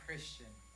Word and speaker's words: Kristhian: But to have the name Kristhian, I don't know.